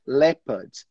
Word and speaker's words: leopard: E aí, porque se você não falar, só para te dar aqui, essa palavra a gente não pronuncia o O. A gente simplesmente fala leopard. Leopard, tá? Leopard.